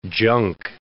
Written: junk

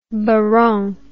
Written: baronne

